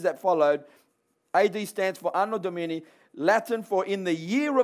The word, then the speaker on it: Anno Domini: Anno Domini.